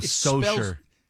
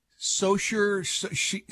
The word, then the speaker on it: Saoirse: Oh, Saoirse. – Saoirse, Saoirse Ronan. – Saoirse Ronan. – Saoirse, I think it is. – Saoirse Ronan. Yeah, anyway. – Saoirse Ronan. – It looks like Saoirse, but anyway, she's playing Linda McCartney, the late Linda McCartney.